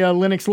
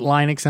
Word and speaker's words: Linux: For those of you who are confused that he pronounces it Linux and I pronounce it Linux, it's just tomato, tomato. It's all the same operating system.